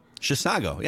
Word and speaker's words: Chisago: Is it Chisago? – Chisago, yeah.